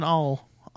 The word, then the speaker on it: awl: And then she finds an awl. I don't want to say all because A-L-L is all.